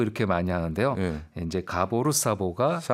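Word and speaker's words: Szabó: 그 가보 사보 가보로 이렇게 얘기한다고 합니다. 반대로 얘기하기도 하고요. 이제 가보로 사보가 맞는 거죠. – 네. 그러니까 Z가 무음이군요, 여기서.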